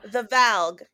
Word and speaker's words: Valg: Is it Vlag or Volg? – I thought it was Vlag. – I think I've been saying it as Vlag as well, but I think it's spelled V-A-L-G. – Yes. Now, remember, my notes kept autocorrecting from Vlag to Flag. So, correct, you know. – The Valg. – Valg. There we go.